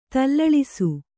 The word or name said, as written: ತಲ್ಲಳಿಸು